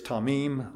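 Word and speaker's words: tamim (תמים): The word is tamim. We would pronounce it T-A-W-M-E-E-M, tamim. It means literally complete, whole, entire, or sound.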